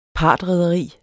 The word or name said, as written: partrederi